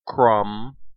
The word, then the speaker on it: crumb: The letter B is usually silent at the end of words in the combination MB, in words of Old English origin. Examples of these words include comb, crumb, dumb, lamb, numb, and womb.